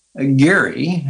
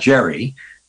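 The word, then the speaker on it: Gerry: And yes, in Massachusetts, Governor Geary, that's I think how we pronounce it, not Jerry, a two-headed salamander in 1824.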